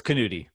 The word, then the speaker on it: Knuti: Close enough. It's Knuti. – Dang it. It is a yeah. K, man. Got it's me. – It's not like knife, but it's close.